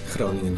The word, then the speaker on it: Groningen: But can you tell us a bit about what the area around, and I'm going to try and pronounce this properly, Groningen? – Groningen. – Groningen, okay. Well, I mean, what's the area like?